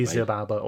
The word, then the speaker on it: Xibalba: Yeah, if it starts with an X, it's pronounced like a Z, isn't it? – Okay. – It'll either be Zibalba or Zibalba, whichever.